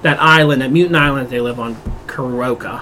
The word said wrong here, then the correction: Krakoa: How do you say Krakoa. – It? Krakoa.